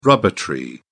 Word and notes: rubber tree: British English

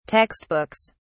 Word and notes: textbooks: British English

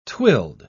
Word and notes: twilled: British English

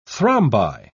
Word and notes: thrombi: British English

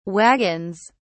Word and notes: wagons: British English

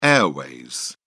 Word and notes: airways: British English

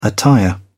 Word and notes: attire: British English

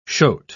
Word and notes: shote: British English

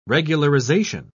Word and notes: regularization: British English

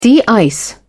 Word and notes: de-ice: British English

